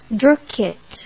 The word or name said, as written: droukit